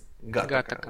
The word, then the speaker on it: Гаттака: Да, третий фильм, собственно, с которого вообще ноги пошли у этого подкаста, это фильм «Гаттака» или «Гатака», или «Гатака», я не знаю, где... – «Гаттака», вот, правильно поставим ударение.